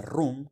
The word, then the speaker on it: Roem: Y ahora es el turno de Room, que se escribe Roem. Ahí empezamos con el idioma holandés a confundirnos a todos. Es una reina que a primera vista me gustó.